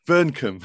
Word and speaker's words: Vearncombe: How do you say Vearncombe? – Vearncombe. – Vearncombe. Yeah, two syllables.